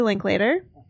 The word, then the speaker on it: Linklater: So the Before Trilogy is films directed by Richard Linklater.